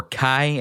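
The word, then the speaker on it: chi: It's the Keyblade, spelled X-Blade, X being the Greek letter key, or Kai as it is pronounced.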